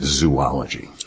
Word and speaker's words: zoology: But, zoology, just to finish the lesson on that, you need three O's in that word if you're going to pronounce it zoology.